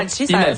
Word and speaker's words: Inalchi: Oui, on prononce pas le L au début, donc c'est bon, déjà, Inalchi.